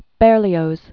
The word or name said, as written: Berlioz